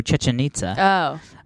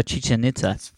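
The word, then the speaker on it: Chichén Itzá: Which has a very, like, I only know how to pronounce that because of Ancient Aliens. – Yeah. – Yucatan? Right. – I love that show. No. Chichen Itza. – Oh. – Chichen Itza.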